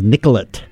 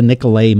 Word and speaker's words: Nicollet: It's like when you go to Minnesota and everything is Nicollet. You gotta pronounce that T. Yeah, so. But we can tell the out-of-towners because it's like, I want to go to the Nicolet Mall and things like that.